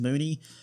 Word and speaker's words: Boutte: I'm going to say Boutte. I've heard that it's pronounced Booty. I'm not going to call this man Booty on my podcast.